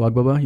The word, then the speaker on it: Agbaba: So just my first and last name, Marko Agbaba. You might have to spell it in so you don't pronounce the G pretty much. Agbaba? – Agbaba. – Agbaba. – It is a G.